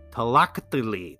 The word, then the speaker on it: Tlachtli: The game is and forgive my pronunciation, Tlactili. Tlactili? T L A C H T L I. Tlactili.